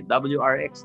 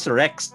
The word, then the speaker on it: WRX: WRX 93. – Oh, wag mo lang ipopronounce as Rex.